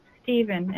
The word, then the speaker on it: Stephen: It's at Carol underscore Stephen, and Stephen is spelled S-T-E-P-H-E-N.